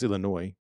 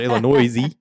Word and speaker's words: Illinois: Yeah, well, it's Illinois, but yeah, silent S. It's not an Illinois-y. Illinois.